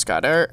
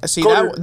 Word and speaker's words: Goedert: So next we got Michael Pittman, and then we've got Dallas Goedert. I did the Goddard. That one wasn't pronunciation. That was enunciation.